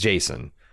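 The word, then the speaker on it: Jacen: But I also think they're undercutting themselves with Sabine and Jaqen. Or Jason? It's Jason. I always pronounced it Jaqen when I was reading it growing up, but I guess it's Jason. Sabine appears to be in her late teens,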